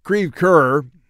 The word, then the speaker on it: Creve Coeur: I also lived in St. Louis, and Creve Coeur, the park where they want to have a lovely evening, is the St. Louis way to pronounce Creve Coeur, which in French can be translated dead heart.